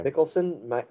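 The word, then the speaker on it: Mikkelsen: Mickelson.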